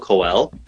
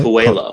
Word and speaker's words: Coelho: My favorite book is The Alchemist by, I can't pronounce his name right, Paulo Coelho. – The Alchemist. – Coelho, yeah.